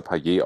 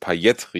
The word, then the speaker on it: Payet: Ähm, wo, ähm, und dort wird er wohl mit hartem T ausgesprochen. Deswegen ist Payet richtig.